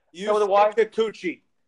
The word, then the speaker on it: Yusei Kikuchi: Yusei Kikuchi.